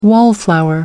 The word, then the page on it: wallflower: American English